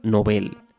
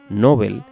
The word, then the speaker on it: Nobel: En su lengua de origen, el sueco es palabra aguda, novel, y así se recomienda pronunciarla en español a pesar de que la pronunciación llana, Nobel, está muy extendida, incluso entre personas cultas.